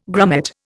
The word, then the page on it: grummet: Indian